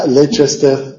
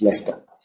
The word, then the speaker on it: Leicester: Y luego, una vez que había muerto el marido, ella encabezó el ejército que reconquistó Derby, que pronunciáis Derby, y Leicester, que lo pronunciáis de una forma abominable. Leicester, ¿ya? Ese es Leicester.